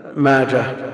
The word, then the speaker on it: ماجه: يقول كلمة ابن ماجه اسم صاحب السنة تنقط وتنطق تاء في الوصل أو هاء مع التوجيه، ماجه ومنده وداسة هي هاء لا تنقط ولا تنطق، ماجه ومنده وداسة كلها بالهاء. يقول